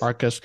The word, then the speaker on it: Arcus: Is that what Arcus, am I pronouncing it right? ARS? – Yes. – Arcus.